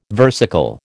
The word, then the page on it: versicle: American English